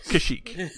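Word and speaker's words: Kashyyyk: It's pronounced Kashik. – Kashik.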